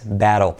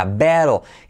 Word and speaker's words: battle: Y se pronuncia como ea, battle.